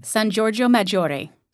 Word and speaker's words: San Giorgio Maggiore: And if I've got the name right, is it, you do the pronunciation San Giorgio Maggiore.